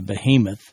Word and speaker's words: behemoth: Well, God uses an illustration from nature here in this chapter when he describes the behemoth. It's a transliterated Hebrew word pronounced the same way in Hebrew, as a matter of fact.